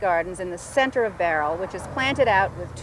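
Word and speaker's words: Bowral: My guidebook says it's spelled Barrel, but it's pronounced Barrel.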